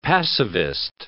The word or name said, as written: passivist